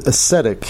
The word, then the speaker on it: ascetic: Yeah, ascetic. So it is a soft E. – Hmm. – A short E, I meant to say.